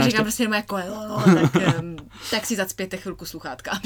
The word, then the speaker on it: Coelho: A je to totiž novinka Paula Coelha, který se správně vyslovuje Coelho, jak jsem se dozvěděl, protože to je Brazilec.